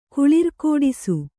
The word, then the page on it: ಕುಳಿರ್ಕೋಡಿಸು: kannada